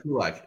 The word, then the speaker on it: Kulacz: I said Kulax. – That's pretty darn good. Okay. That's good enough. That's good enough. – Say it for me. – I say Kulak, but Kulak. Oh, you don't say the Z? – Oh boy. I've heard it's silent Z. I've also heard it should be pronounced as Q watch, but who knows?